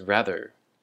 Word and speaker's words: rather: And our next word is rather. Rather. And the emphasis is on the first syllable, the ra.